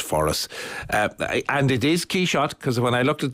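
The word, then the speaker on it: Quichotte: But the reason why it's Quixote in this case is that our character hears the Massenet opera, which was by the French composer. Mm. So Quixote is the French pronunciation of Quixote.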